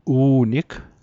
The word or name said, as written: u'nig